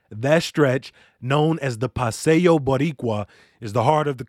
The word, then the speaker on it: Boricua: It's pronounced Paseo Boricua.